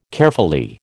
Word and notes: Caerphilly: American English

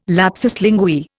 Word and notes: lapsus linguae: Indian

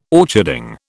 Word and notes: orcharding: British English

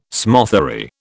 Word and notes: smothery: British English

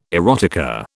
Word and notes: erotica: British English

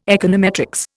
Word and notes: econometrics: Indian

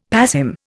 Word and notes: passim: Indian

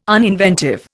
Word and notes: uninventive: Indian

